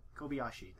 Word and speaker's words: Kobayashi: Kobayashi. That's how you say it.